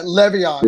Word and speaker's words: Le'Veon: It's Le'Veon Bell. Is that how you say it? Le'Veon? – Yeah, Le'Veon.